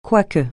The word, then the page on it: quoique: francais